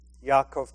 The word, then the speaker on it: Yaakov: James chapter 5 and verse 16 reminds us, the book of Yaakov, properly pronounced, Yaakov chapter 5 and verse 16, confess your trespasses one to another and pray for one another that you may be healed.